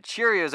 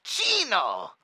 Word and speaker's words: Chino: You know just as well as me that Cheerios over here pulled the trigger. – It's pronounced Chino. Chino, it's not hard. C-H-E-E-E-E-N-O. Chino, it's Chino.